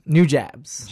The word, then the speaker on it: Nujabes: His name, I think it's New Jobs or New Jobs. I don't know how to say his name. N-U-J-A-B-E-S. – Jabs. – New Jabs. – Jabs, there we go with him.